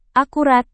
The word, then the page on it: akurat: indonesian